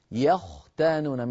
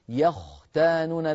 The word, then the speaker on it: يختانون: لما تقول يختانون فخم الخاء، يختانون من الياء المرققة إلى الخاء المفخمة، انطقها. انقطع الاتصال. إذن أخي محمد، يختانون لابد أن نفخم الخاء ولا نقول يخ، إذا قلنا يخ نرقق الخاء والخاء ليست من حروف الترقيق.